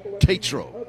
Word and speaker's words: Tatro: But once again, your referee, the man in the white hat tonight, Jeff Tatro, with a long A.